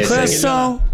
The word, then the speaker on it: Curaçao: Sì, Curaçao.